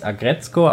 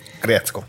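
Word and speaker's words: Aggretsuko: Das Ding ist auch auf Netflix, heißt Agretzko. Mhm. Geht gerade voll durch die Wand. Also das schreibt man Agretzuko, aber man spricht es Agretzko aus. – Agretzko.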